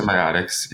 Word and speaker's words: Mycosymbiotics: Mycosymbiotics. – The Mycosymbiotics, yeah.